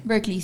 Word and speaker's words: Berkeley: ¿Y cómo fue tu tiempo en Berkeley? ¿Lo pronuncio bien? – Berkeley, sí. Berkeley. Está bien.